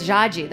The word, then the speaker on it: Jade: Like, I told you guys in Portuguese, that means it's Jaji. That's how the way you pronounce it.